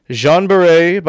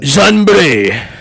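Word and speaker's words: Jeanne Baret: Jean Barret, by means of disguise. – Say it like a French person. Like a French hierarchy. – Like Jean Bray.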